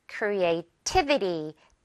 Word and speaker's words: creativity: It's a great way, 뭐 할 수 있는, to increase creativity. T에 강세 있어요.